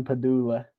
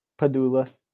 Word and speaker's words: Padula: Yeah, John Padula. – Padula? – Padula. – Padula. – Yeah. – Like the brain thing? Padula Oblongata? – Padula Oblongata.